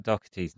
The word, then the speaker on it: Doherty: How do you say Matt Doherty's name? – You've more or less got it. Like you're not. You're like a soft CH, but like the C's not there.